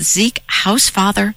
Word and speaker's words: Zeke Housefather: You quote, is it Zeke Housefather? How do you pronounce his name? – That's right, his father.